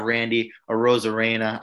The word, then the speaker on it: Randy Arozarena: I have Randy Arozarena.